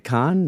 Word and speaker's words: Cannes: Oh, yeah, we're calling it Cannes now. Okay. – Yeah, apparently that's how you pronounce it.